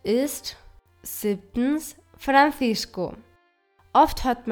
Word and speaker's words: Francisco: Wenn nach einem C ein I folgt, wird es weich ausgesprochen, wie ein Z. Ich meine Francisco. Das C nach dem O wird als K, also stark ausgesprochen.